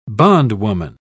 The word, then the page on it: bondwoman: British English